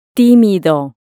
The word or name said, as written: timido